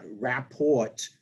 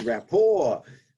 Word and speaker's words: rapport: Yeah, it's not rapport, by the way. No. It's rapport, a French word. – And it's spelled R-A-P-P-O-R-T, so that's why you were joking about it, because it ends with a T, but it's a silent T for the French word, right?